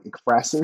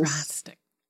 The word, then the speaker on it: ekphrasis: Or like ekphrasis. – Frastic. – Yeah. – Frastic with an F? – No. Like E-K-P-H-R-A-S-I-S.